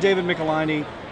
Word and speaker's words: Michelinie: So you got the gig, and between you and David Michelinie, you're putting out... – Oh my God, you pronounced it right.